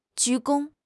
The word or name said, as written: 鞠躬